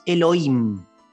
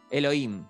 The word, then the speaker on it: Elohim: la H es muda, es Elohim. – Elohim.